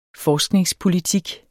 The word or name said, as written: forskningspolitik